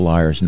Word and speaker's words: Lyres: Liars not long after that, and that is Liars. If you're not familiar with the band, it's spelled L-Y-R-E-S, like the ancient Greek instrument.